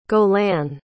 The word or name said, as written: gaulin